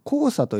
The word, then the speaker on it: 黄砂: 黄砂というのは何かというと、あの黄色い砂と書いて黄砂と読みます、ね、黄色い砂。